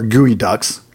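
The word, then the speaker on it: geoduck: And so you've got shrimp, you've got salmon, you've got geoducks, or gooey ducks. I know I always say that wrong.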